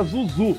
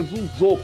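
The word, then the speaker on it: Zhuzhou: O Rodrigo ficou tentando, falando que era Zuzu, só que é Zuzô, porque é o caso da última sílaba.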